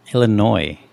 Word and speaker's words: Illinois: Illinois. You live here. You don't pronounce the S.